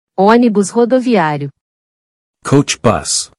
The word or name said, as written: Onibus Rodoviario